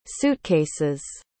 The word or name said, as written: suitcases